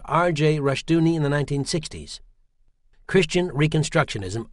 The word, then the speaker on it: Rushdoony: Rushdoony, rhymes with Rush Looney, pioneered the Christian homeschooling movement and came up with the revisionist history that paints the U.S.